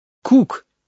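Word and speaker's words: Cook: Это пишется «коок», а читается «кук» по-английски. Правда, там на конце буква «К» другая.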